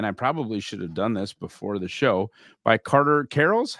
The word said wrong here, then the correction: Carrolls: How do we pronounce the last name? – Close. It's Carrolls. – Carrolls.